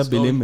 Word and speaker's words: Belém: Das ist ja in Belém, wie spricht man das aus? Irgendeine Stadt in Brasilien? – Ja, Belém, ja.